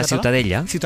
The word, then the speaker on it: Ciutadella: La Ciutadella.